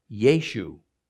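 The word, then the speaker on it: Yeshu (ישו): And when you pronounce it, it comes out Yeshu.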